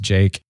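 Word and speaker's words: JVKE: V-K-E is what it is, but it's pronounced Jake.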